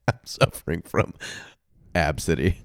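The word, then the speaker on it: Abcde: How is it pronounced? – How would you pronounce it? – Abkada? – No. They turned it... – Probably Megan? – They turned it into a oddly pronounceable name, which she can go fuck herself, but Absidy.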